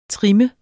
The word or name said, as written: trim